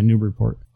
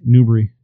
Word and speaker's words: Newburyport: Yeah, Newburyport. – Oh, how do you say it again? – Newbury. – Newburyport. – Yeah, they never say airy.